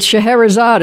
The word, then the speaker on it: Scheherazade: Well, I know one address you'll never forget, and that's Alibaba Avenue and Scheherazade Boulevard. – Scheherazade Boulevard. – Sh- Shahrazad? – Yeah, right. It's Shahrazad is how it's pronounced.